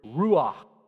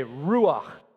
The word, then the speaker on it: ruach: Everyone say ruach. Kind of got to say it like there's peanut butter stuck on the back of your throat. That's how it works. Ruach. But it sounds like a whoosh, because it means breath or wind.